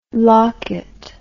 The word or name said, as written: lockit